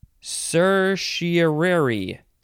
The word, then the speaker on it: certiorari: It is certiorari. Certiorari or certiorari or certiorari. That's the same. Um, it is spelled C E R T I O R A R I.